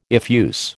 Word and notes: effuse: American English

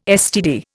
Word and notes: STD: Indian

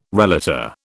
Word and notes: relator: British English

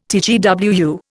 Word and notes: TGWU: Indian

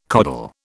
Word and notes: coddle: British English

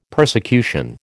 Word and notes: persecution: American English

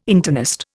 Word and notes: internist: Indian

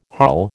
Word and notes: harl: American English

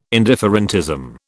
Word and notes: indifferentism: British English

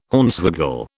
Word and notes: Hornswoggle: British English

